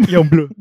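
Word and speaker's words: Yung Bleu: Young Blue.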